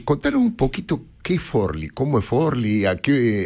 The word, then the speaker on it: Forlì: Sí, y Forlì, en realidad es Forlì, es un nombre... – Ah, Forlì se pronuncia, sí, es un nombre raro, no, imagínate.